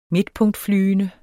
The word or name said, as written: midtpunktflyende